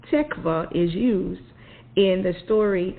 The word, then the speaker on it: tikvah: So as I went through, the Hebrew word for hope is the word tikvah, and it's spelled T-I-K-V-A-H.